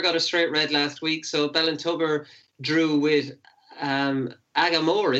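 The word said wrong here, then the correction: Aghamore: is it? – Ahamore. – I should have known the Connacht pronunciation of that.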